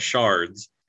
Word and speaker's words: sherd: It's a shard of a pot. So think of clay pot. Once it's broken, you've got shards, and they've got sharp edges. So, but it's spelled S-H-E-R-D, like a herd.